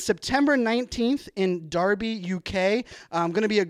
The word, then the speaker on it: Derby: I was pronouncing it Derby, but I was corrected earlier this morning. It is Derby. I'll be in Derby.